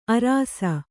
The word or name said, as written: ಆರೈಸು